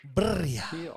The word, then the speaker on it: birria: In Spanish, in Mexico, it would be said as birria with a double R. – You do there's want 20 to R's in there. – Double R if you're pronouncing it in Spanish. Birria.